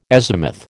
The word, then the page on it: azimuth: American English